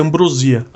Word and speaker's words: ambrosia: E sendo muito chato, é, o, a pronúncia é ambrosia.